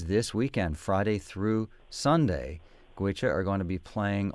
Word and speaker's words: Goitse: It comes off of the self-titled CD by the traditional Irish band from Ireland called Gwicha, which is spelled G-O-I-T-S-E.